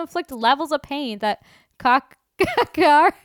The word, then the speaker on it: Kakihara: I was so close. The H is silent. Kakihara. Daddy searches for his missing boss.